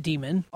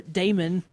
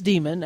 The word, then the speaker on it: Daemon: So anyway, the book is called Demon, D-A-E-M-O-N, and it is pronounced Demon. All this time, Matt and I have had a difficult time deciding or getting over our Damon. How we pronounced it, but it is Demon after doing some research on that.